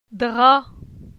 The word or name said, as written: Dra